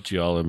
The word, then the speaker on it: giallo: I love giallo movies, and like I really had a what other giallo, is that how you say it? – Giallo, yeah. – Giallo.